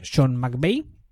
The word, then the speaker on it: Sean McVay: Los Rams ah, también eh, empiezan nueva etapa porque han contratado a nuevo entrenador, que es Sean McVay. No le he bautizado, ¿verdad? Lo digo bien el nombre. – No, no, lo has dicho perfecto.